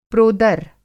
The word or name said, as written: प्रोदर